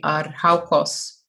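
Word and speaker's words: Arhuacos: We're speaking now, and tell me if I'm pronouncing this right. We're speaking of the Arhaucos people. Is that right? – Yeah.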